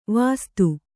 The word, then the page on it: ವಾಸ್ತು: vāstu